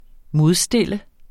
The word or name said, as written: modstille